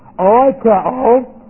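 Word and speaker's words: Oikeo (οἰκέω): Oikeo. O-I-K-E long O. Oikeo.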